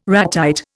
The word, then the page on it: ratite: Indian